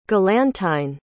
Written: galantyne

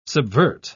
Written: subvert

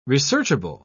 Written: researchable

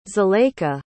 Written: zuleika

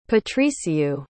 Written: patricio

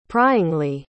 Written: pryingly

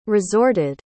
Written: resorted

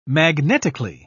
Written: magnetically

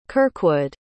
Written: kirkwood

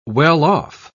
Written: well-off

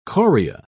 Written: Coria

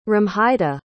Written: romhilda

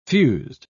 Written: fused

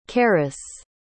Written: karis

